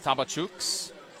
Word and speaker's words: Tabacuks: T-A-B-A-C-U-K-S, pronounced Tabachuks.